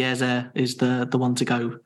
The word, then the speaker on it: Eze: So if we are going to go with his surname, then I think it's Eze.